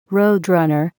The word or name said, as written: roadrunner